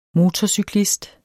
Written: MOTORCYKLISTER

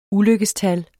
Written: ulykkestal

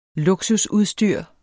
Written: luksusudstyr

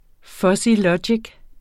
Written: fuzzylogik

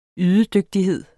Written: ydedygtighed

